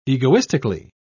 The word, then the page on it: egoistically: British English